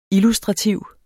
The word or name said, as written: illustrativ